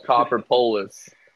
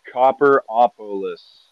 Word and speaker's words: Copperopolis: I always call it Copperpolis. – I remember that. – I always say Copperpolis. Justin's like, it's not Copperpolis. It's not Copperpolis. How do you say it? – Copperopolis. – Copperopolis. Like, Copperopolis. Gotta sound that shit out. Sound it out.